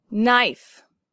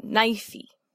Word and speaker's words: knife: Knife. Now, the K in the beginning and the E on the end are silent, so it's not knifey.